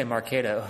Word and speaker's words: Marketo: Yeah, I say Marketo.